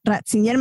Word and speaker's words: Ratzinger: No, ¿por qué me pones siempre Yo no sé cómo se pronuncia ese nombre en alemán, pero sí sé que Ratzinger se pronuncia Ratzinger,